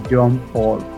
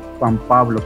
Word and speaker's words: John Paul: Así se pronuncia John Paul, no Juan Pablo, como dicen la chavista.